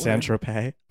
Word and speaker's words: Saint-Tropez: Wonder if that Saint-Tropez... Is that the right word? – Saint-Tropez. – Oh. – You put the emphasis on the wrong syllable.